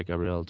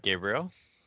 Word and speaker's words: Gabriel: Hi, Gabriel. Did I pronounce that correctly? – Gabriel, but thank you for having me.